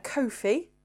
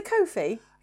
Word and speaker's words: Ko-fi: It's supposed to be like coffee, but it's spelled like Ko-fi, like in Kofi Annan.